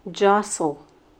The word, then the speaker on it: jostle: Word number 11, jostle. Jostle. This word is spelled J-O-S-T-L-E, but we don't pronounce that T, jostle.